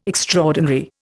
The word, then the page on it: extraordinary: Indian